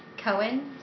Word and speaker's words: Cohen: Okay, so Leia, L-E-A-H, Cohen, C-O-H-E-N, hyphen, S-A-P-I-D-A.